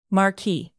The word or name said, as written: marquis